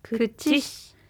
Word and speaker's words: くちし: 知しの「し」は小さい「し」なので軽く発音するように気をつけましょう口くちし